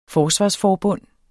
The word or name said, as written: forsvarsforbund